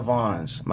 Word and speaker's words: Vaughns: At Dana Vaughns. My last name is spelled V-A-U-G-H-N-S.